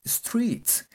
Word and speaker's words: street: S, T, R, E, E, T y se pronuncia como si fuera una I. Street, street.